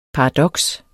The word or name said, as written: paradoks